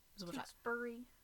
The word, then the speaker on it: Tewksbury: No, like, if, like, it's spelled Barry, B-U-R-Y, and yeah. It's, like, Barry we pronounce with an A. – Tewksbury.